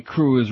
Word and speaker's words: Cruz: It's hurtful to him and to his loved ones, especially his girlfriend, Penelope Cruz, rhymes with schmooze and lose.